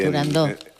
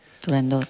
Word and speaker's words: Turandot: Turandot. – Turandó. – Turandot. – No. – Turandó. – No. No se dice, no se debe pronunciar Turandot. – No, Turandó. – La ópera se titula Turandó.